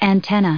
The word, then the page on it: antenna: noun